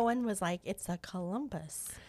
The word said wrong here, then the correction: cumulus: It's a cumulus.